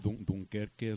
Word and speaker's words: Dunkerque: Yo sé que Dunkerque. Se dice así, güey, pero eso no es muy malo. – No solo eso, es el nombre original.